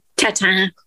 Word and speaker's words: tatin: Tatin? – Tatin. – Tatin. – And I'm not French, but you know. – You're more French than me because you can pronounce tatin. – It's the nasal sound at the end.